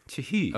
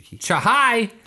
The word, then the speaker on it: Chahi: C H A H I with some googly accents or whatever they call him. – It looks like because it's C H A H I. Chahai! Like, that's how I pronounce it.